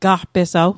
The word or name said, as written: gaqpesaw